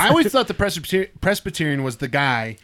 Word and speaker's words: Presbyterian: Eat it's Presbyterian.